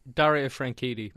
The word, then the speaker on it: Franchitti: Dario Franchitti.